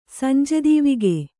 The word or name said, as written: ಸಂಜೆದೀವಿಗೆ